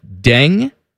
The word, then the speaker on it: Deng: His name is Deng. Uh-huh. First name, D-E-N-G, okay. Deng. – Deng. – His last name is spelled N-G-O-R, which makes me extremely...